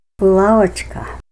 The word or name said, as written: bulawachka